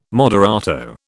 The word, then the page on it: moderato: British English